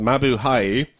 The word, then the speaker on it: Mabuhay: Mabuhay, our native language, pronounced Mabuhay, which means greetings, to the humorous yet sensible guys at Lug Radio.